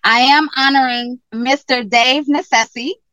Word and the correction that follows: Nassani: FYI, it's pronounced Nassani.